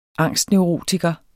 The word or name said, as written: angstneurotiker